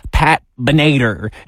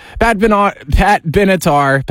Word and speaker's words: Pat Benatar: I said, with conviction, into the mic, Pat Benatar. And then immediately I got a text message saying, that's not how you pronounce that artist's name. Pat Benatar.